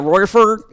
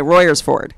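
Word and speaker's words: Royersford: Yeah, Royers Ford. – Yeah. – Okay, Royers Ford. Yeah, because I've been trying to say it on my podcast, so now I'll say it correctly.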